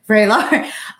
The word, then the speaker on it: Vraylar: Did I say it right this time? – Vraylar.